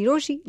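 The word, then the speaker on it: Rochie: मेरा नाम है मिर्ची रोशी, नाम की स्पेलिंग है RO CHIE.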